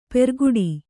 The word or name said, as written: ಪೆರ್ಗುಡಿ